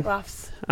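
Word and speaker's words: Haglöfs: I say Hagloffs, but... – Yeah, that's good. – Is that good? – Hagloffs.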